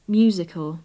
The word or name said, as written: musical